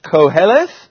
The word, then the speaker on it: Koheleth: The word is Koheleth, pronounced Koheleth.